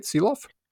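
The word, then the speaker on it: Silovs: Did I say that right? Seeloff? – There's a few different pronunciations. You are hitting the most Canadian one, yes.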